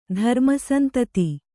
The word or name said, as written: ಧರ್ಮಸಂತತಿ